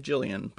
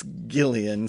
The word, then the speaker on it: Gillian: But I'm a big fan of Gillian Jacobs, and even though I feel weird calling her Gillian, but apparently that's how you say it. I always want to say Jillian, but...